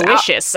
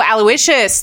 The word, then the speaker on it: Aloysius: Aloysius, I think. – Oh, Aloysius. That's how you spell that. Aloysius. – I think so, yeah.